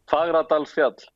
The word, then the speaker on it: Fagradalsfjall: Fagradalsfjall.